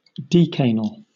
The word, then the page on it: decanal: British English